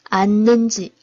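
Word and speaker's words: 앉는지: 가 슴 이 먼 저 왜 내 려 앉 는 지，仍然这个音变呢啊，同学们注意 안 는 지 啊，就是后边的这个是不发音的。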